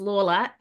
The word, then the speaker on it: Lawler: Okay, but my last name is Lawler, LAWLER. Two syllables. One is a real word, law. One of them is pretty easy, lur.